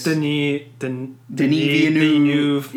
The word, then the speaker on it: Denis Villeneuve: Blade Runner directed by Dennis. – It's Denis Villeneuve. – Yeah. French-Canadian. – Yeah. We kind of mispronounced it previous episodes, but he directed Arrival, Sicario.